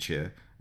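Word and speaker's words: chu (曲): So one of the important things when you're distilling baijiu is the, and I'm going to try and say this one correctly, chu, which is Q-U. Not pronounced chu, but chu. So all baijiu is made with chu, which is a composite of yeast and mould cultivated with grains and then formed into cakes or balls.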